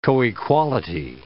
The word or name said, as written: coequality